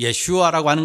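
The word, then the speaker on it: 예슈아 (ישוע): 이 예수의 정확한 발음은 예슈아입니다. 이 예슈아라고 하는 것은 구원이라고 하는 뜻을 가지고 있어요.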